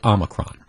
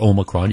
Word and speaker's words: Omicron: Well, this variation, they call it Omicron, and or Omicron, you can pronounce it either way, that's fine.